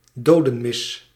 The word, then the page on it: requiem: American English; British English; /ˈrɛkwiˌɛm/; /ˈrɛkwi.əm/